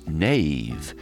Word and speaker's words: Nave: And Nave is spelled N-A-V-E. And just a little aside, my family name is pronounced Nave, N-A-V-E. And somebody once said to me years ago, oh, Nave is fine, but Nave is so much more fun. So that's how Nave came to be.